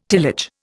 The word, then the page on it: tillage: Indian